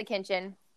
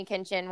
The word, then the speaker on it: Kinchen: So it's like, it's spelled like kitchen, but with an N instead of a T. So K-I-N-C-H-E-N. But everyone mispronounces it and calls me Kitchin all the time. So my name is The Heat because if you can't take the heat, stay out of the kitchen.